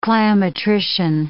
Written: cliometrician

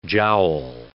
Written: jowl

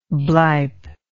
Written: blype

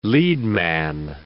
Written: leadman

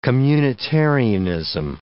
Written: communitarianism

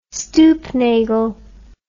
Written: stoopnagel

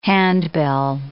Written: handbell

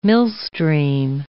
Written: millstream

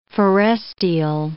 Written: forestial

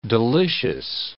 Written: delicious

